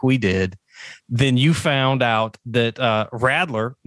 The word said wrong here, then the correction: Radler: no, no, I said that wrong. How do you say it? – Rodler. – Rodler. See, it looks like Radler because I'm from the South.